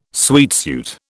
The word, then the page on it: sweatsuit: British English